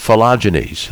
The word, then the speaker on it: phylogenies: It has to do with phylogenies. My first question is, did I say that right? – You did.